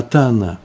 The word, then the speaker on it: Тана: Причем Барайту приводит она, это не всегда так пишется, э, не Таня написано, а Тана. Тана – это значит «учил».